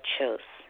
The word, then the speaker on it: Kjos: But they're all published by a company called Chose.